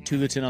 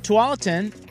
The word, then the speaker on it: Tualatin: It was the Jensen brothers, not the Bryan brothers, the Jensen brothers, the tennis brothers who pronounced it Tulleton on the radio. That's our final answer on that. At any rate, X Golf Tualatin.